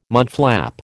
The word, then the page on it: mudflap: American English